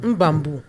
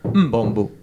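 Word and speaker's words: M'bambo: Quand il y a juste un M apostrophe, on dit juste Mbambo. – Mbambo.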